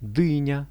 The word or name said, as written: dynia